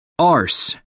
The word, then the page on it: arse: American English